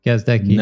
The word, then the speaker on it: Gazdecki: Did I say your name correctly? – You did. Gazdecki.